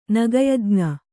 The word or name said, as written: ನಗಯಜ್ಞ